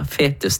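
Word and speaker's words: foetus: I ord som øvre og fetus, eller fetus da, som det faktisk uttales. Der, ja. Den E-en er bare sånn det blir uttalt. Fordi at dette kommer fra latin.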